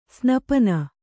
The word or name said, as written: ಸ್ನಪನ